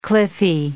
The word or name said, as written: cliffy